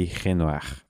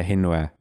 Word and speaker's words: Renoir: Eu, eu, acho que o último R não é pronunciado, acho que é Renoir.